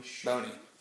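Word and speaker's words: Bony: I think Michu and how do you pronounce it, Bonnie. – Boney, yeah.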